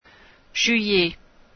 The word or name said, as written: juillet